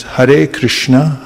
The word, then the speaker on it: Hare Krishna: It's pronounced Hare Krishna, Hare Krishna, Krishna Krishna, Hare Hare, Hare Rama, Hare Rama, Rama Rama, Hare Hare.